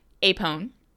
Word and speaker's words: Apone: Okay, lastly, but not least, we have Elle Matthews, who plays Sergeant, am I saying that right? – Sergeant Apone. – Sergeant, thank you. Yeah, I was saying that wrong. Apone.